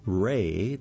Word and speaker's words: Wray: Link Ray, that's spelled W-R-A-Y,